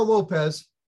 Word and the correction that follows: Lopes: Lopes. – Yeah. I always say that wrong.